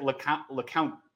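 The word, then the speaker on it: LeCounte: Chris Trapasso pronounced it LeCount, I believe, on our podcast. It threw me off. – I've heard it pronounced to me by Browns people as LeCount, so I'm going to trust that.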